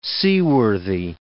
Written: seaworthy

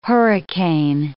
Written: hurricane

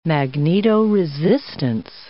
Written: magnetoresistance

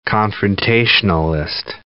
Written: confrontationalist

